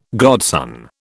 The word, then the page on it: godson: British English